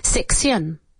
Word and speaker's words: section: Section. Es decir, se escribe la segunda sílaba T-I-O-N, pero no es tion, es shin, como si fuera S-H-E-N. Section.